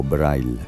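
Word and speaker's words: Breil: Braille, o Braille, pronunciado a la alemana, considerado uno de los primeros profesionales de bandas sonoras.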